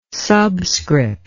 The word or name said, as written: subscript